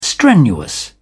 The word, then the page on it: strenuous: British English